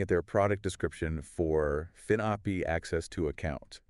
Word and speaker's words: finAPI: It's spelled FINAPI, Right. – but it's pronounced FinOpi as one word.